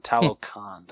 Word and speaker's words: Talokan: Show Shuri his vibranium-rich underwater kingdom of Talokan. Is that how you pronounce it? – Talokan.